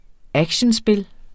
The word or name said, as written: actionspil